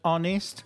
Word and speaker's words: honest: With the word honest, like honest man, we use an because the H is silent.